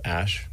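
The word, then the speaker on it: Æ: Letter X, and then the A-E is like pronounced Ash, and then A-12 is my contribution.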